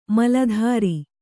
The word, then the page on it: ಮೊಲೆತೊರೆ: mole tore